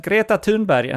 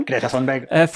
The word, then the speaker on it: Greta Thunberg: For insisting that Greta Thunberg, and that is the correct pronunciation. – Greta Thunberg.